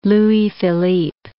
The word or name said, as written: Louis Philippe